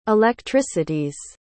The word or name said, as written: electricities